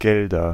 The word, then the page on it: Gelder: /ˈɡɛldɐ/